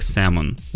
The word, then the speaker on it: salmon: Это э, пишется как salmon, но произносим его как salmon. Эта буква L не читается.